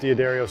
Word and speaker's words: D'Addario: For my whole career, I've used D'Addario strings.